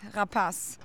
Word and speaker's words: Rapace: It is French, you know that, right? Rapace.